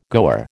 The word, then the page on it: goer: American English